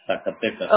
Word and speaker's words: Zacatecas: Zacatecas. – So which one do you want to use? – Zacatecas.